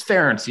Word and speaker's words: Ference: He's like, yeah, it's Ference, you know, rhymes with Clarence or parents, but whatever.